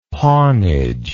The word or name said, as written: pawnage